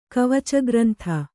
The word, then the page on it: ಕವಚಗ್ರಂಥ: kavaca grantha